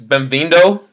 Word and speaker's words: bem-vindo: Bem-vindo, if that's the right way to say it.